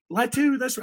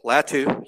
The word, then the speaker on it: Latu: Am I saying that right? Latu? I think that's right? – That's how I say it. Latu. Yeah, I'm pretty sure that's right.